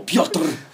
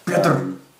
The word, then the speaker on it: Pyotr: Peter Pyotr. That's one syllable, Pyotr. – Pyotr.